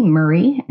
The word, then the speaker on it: Murray: So you can find me online at Betty Murray, and the last name is spelled M-U-R-R-A-Y.